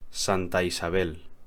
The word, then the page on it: Santa Isabel: Spanish